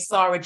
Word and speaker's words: Sara: and also my personal page Sara Jayne, but you have to spell it S A R A space J A Y N E.